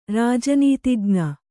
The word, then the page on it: ರಾಜನೀತಿಜ್ಞ: rāja nītijña